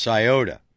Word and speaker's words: Scioto: That's right, it's pronounced Scioto with an A, like Cincinnati.